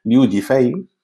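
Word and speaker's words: Liu Yifei: os lo digo bien, Liu Jifei,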